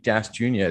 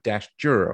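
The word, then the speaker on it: Dashjr: Apparently, it's not Luke Dash Junior. It's Luke Dashger or something like that.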